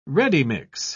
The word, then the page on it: ready-mix: British English